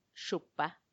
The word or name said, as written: xupa